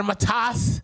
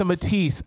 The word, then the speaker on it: Matisse: That a Matas? No, sir. It's a Matisse.